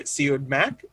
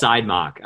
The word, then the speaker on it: Siodmak: It was directed by Robert Siodmak, German director, I believe. – I think it's Siodmak, I think. Is actually Siodmak? – I think it's how he pronounces it.